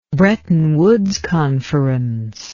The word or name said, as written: Bretton Woods Conference